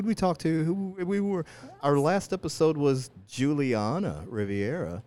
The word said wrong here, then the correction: Rivera: Why do I keep butchering her name? – Because you want her to be from the Riviera. – I want her to be from the Riviera. – Yeah. Rivera.